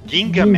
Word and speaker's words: Gingaman: Nossa, é de 98 Gigaman, cara! É. – Gingaman, Gingaman.